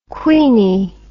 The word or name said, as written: queeny